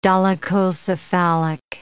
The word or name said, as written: dolichocephalic